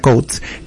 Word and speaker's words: Coats: Y la enfermedad de Coats, que se pronuncia Coats, que también es otra causa de leucocoria, son vasos anormales ahí adentro de la retina que generan sangrado, fibrosis, tracción.